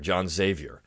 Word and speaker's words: Sevier: I was actually reading a book about John Sevier, which if you pronounce his name properly, was Xavier, John Xavier. But we know him today as John Sevier.